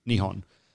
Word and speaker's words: Nihon: No, it's like Nihon or Nippon. Yeah, for some reason, it gets pronounced with a P sometimes, and sometimes it's an H.